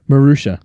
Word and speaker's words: Marussia: Marussia.